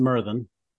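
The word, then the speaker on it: Myrddin: Merlin is they pronounce it Merthin.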